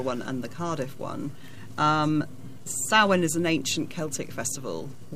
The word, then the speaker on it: Samhain: So is this tied up with, if I'm pronouncing this right, Samhain? – Samhain. I'm going to pass that one over. – Yes, it is. It is Samhain.